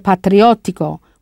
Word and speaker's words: patriottico: Yes, è patriottico, with that double T. In Italian, abbastanza, spelled A-B-B-A-S-T-A-N-Z-A, means enough.